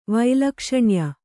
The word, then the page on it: ವೈಲಕ್ಷಣ್ಯ: vailakaṣaṇya